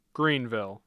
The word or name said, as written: Greenville